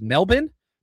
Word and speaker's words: Melbourne: I've been corrected several times by the Aussies that it's pronounced Melbourne, not Melbourne. It's spelled Melbourne, but it's Melbourne.